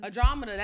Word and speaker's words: Andromeda: Andromeda, what's her name? – Andromeda, that's how you say it, but what we called her.